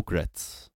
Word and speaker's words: Gritz: And I guess some of the people that were there were just like, Gritz, it rhymes with whites. And I was like, Gritz does not rhyme with whites.